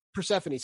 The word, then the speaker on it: Persephone: So for example, in kind of the Greek one that we're probably most familiar with, Persephone, I can't remember pronounce her name. She comes home from the summer. Go ahead and say it again. – Persephone. – Sorry. Thank you.